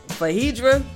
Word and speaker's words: Phaedra: I don't want to mess up her name. – Oh, that's right. Okay. – So I'm going to say his name, which is... – Fahedra, right? – Fahedra, there you go.